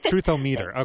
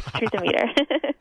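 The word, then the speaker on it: Truth-O-Meter: How do you guys say it there? Truthometer, okay. – Yeah, we were talking about pronunciation today. Yes, Truthometer.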